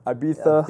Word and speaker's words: Ibiza: Call it Ibiza? – Ibiza. Ibiza. Yes, that's the proper way to say it.